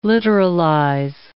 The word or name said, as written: literalize